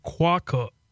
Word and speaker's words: quokka: So it's quokka. Quokka. – Okay. – Quokka. That's how you say it.